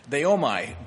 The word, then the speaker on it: deomai: The third word that is used for prayer is deomai, D-E-O-M-A-I. D-E-O-M-A-I is the English way to write it out phonetically.